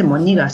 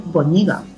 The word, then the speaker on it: boñiga: O sea, y además es lo que estaba lo que he puesto también en el blog, que por lo visto no se dice moñiga, se dice boñiga.